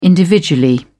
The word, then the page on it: individually: British English